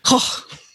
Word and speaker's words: Koch: Koch.